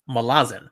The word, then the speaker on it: Malazan: That's how Steven Erikson says it. The author says Malazan. That was his intent.